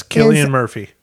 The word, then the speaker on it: Cillian: Killian, I think his name is pronounced. – Yes, Killian Murphy.